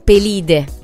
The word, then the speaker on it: Pelide: Quindi, a proposito, ritornando al proemio dell'Iliade, canta mio diva, lira funesta del Pelide, l'accento andrebbe sulla I,